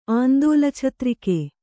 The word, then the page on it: ಆಂದೋಲಛತ್ರಿಕೆ: āndōlachatrike